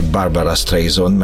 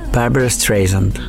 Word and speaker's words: Barbara Streisand: Szándékosan ejtettem így a nevét, hogy Barbara Streisand, mert ugye mindenki így ejti itt szerintem hazánkban, vagy elég sokan. Na de hogyan is ejtik őt szépen? – Barbara Streisand. – Streisand? Vagy Barbara Streisand. Streisand. Igen, egy picit másképp ejtik, mint ahogy mi itt magyarosan szoktuk.